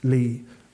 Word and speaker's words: Legh: I gave her my name, James, and spelled out my surname by instinct, L-E-G-H, pronounced Lee, but she understood straight away.